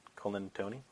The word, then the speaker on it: Colantoni: Did I do it well? Colantoni, right? – I think so.